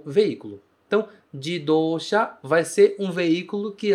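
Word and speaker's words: jidosha (自動車): Carro ou automóvel em Nihongo se diz jidosha. Jidosha. A gente usa três caracteres diferentes para formar esta única palavra.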